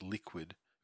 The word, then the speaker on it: LIKW3D: I presume it's pronounced Liquid. It's LIKW3D. Headline.